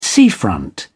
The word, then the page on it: seafront: British English